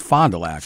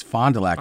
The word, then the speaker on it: Fond du Lac: It's pronounced Fond du Lac. – Fond du Lac. – And he's like, no, it's Fond du Lac.